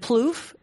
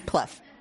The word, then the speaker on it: Plouffe: So if I say the name David Plouffe, everyone knows who that is, basically. That's one of those dudes. – Mm-hmm. Plouffe. – She's even correcting. I'm not saying his name right.